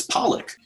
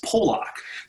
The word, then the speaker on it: Pollock: And the answer to that is Pollock. Stephen King's father was Pollock. And the thing is, back in the, the way it's spelled, some people might mispronounce it as Polak.